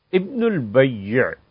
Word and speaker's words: ابن البیع: ابن البیع تشدید کے ساتھ، بیع اور آخر پر عین کے ساتھ، عام طور پہ لوگ اس کے تلفظ میں غلطی کرتے